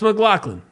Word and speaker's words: McLaughlin: And he's like, "Oh, it's McLaughlin. That's McLaughlin. I know it's spelled weird, guys, but it's McLaughlin.